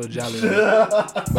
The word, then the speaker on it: Jahleel: Well, her name is spelled Jaleel, so it's like J-A-H-L-E-E-L. So Jaleel.